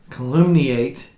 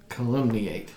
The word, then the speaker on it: calumniate: calumniate this world. And yes, we had to look up how to pronounce calumniate. – Yes, as in calumny.